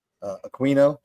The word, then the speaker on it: Aquino: Aquino, she pronounces the Q like that.